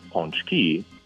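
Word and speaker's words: pączki: The real way to spell it is going to be P-A with a little tail, C-Z-K-I, because what that tail on the A means is that that A is not a A, but a on. And that's where ponczek and ponczki are pronounced.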